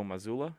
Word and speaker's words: Mazzulla: Team Giannis is being coached by the Boston Celtics coach Joe Mazzulla. Is that how you say it? – Yes.